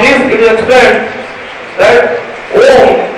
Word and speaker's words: Om: The Om sound has three syllables. That is A, U and Ma.